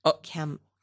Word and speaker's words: Camus: It's spelled C-A-M-U-S, Alfred, Camus. – Camus? – Yeah, I'm not French.